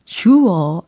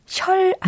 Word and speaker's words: sure: S-U-R-E 라는 철자를 가진 단어죠. 발음은 sure 아니고, 셜 아니고, sure. 들으셨죠? sure.